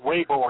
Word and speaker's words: Weber: It's actually pronounced Weber, and it's Dutch.